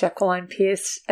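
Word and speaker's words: Jacqueline Pearce: And, in fact, I think in an interview with Jacqueline Pierce, and that's how she does pronounce her name. – Jacqueline Pierce.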